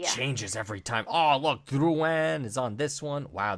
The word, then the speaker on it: Drouin: Drouin.